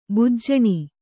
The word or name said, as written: ಭೂಜಾನಿ